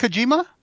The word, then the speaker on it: Kojima: Kojima, the creator of the Metal Gear Solid series. – Oh, is that Kojima? – Hold on a second.